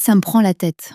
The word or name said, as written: ca me prend la tete